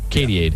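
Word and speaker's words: Katy-ade: The 41-year-old pop star climbed into a giant transparent inflatable bottle nicknamed, I think this is how you say it, Katyade? – Yep. Like Gatorade, but Katyade? – Yeah, Katyade.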